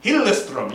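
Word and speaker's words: Hillström: Hillestrom, yes, from Sweden.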